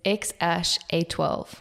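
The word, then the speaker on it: X Æ A-12: It's actually, so just FYI, for everyone to know how it is pronounced, it's X-A-12. X-A-12, I think.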